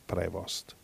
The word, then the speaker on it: Prevost: Guarda, stavo dando un'occhiata qui ai giornali, un attimo, e che questo si chiama Prevost, in italiano si legge Prevost.